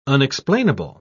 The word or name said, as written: unexplainable